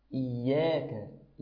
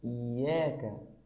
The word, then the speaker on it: إياك: هذا هو النطق الصحيح الذي أريده منك في حرف العين، ولكن أشعر وأحس بأن كلمة إياك قد تخرج منك أرق من ذلك. إياك إياك وإياك وإياك بهذا النطق، وكلمة نستعين نطقها طيب كما قلتها.